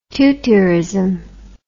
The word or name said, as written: tutiorism